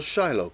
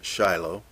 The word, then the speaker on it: Shiloh: And of course, the Hebrew translation is a little different, Shiloh. But most of us are accustomed to pronouncing it Shiloh.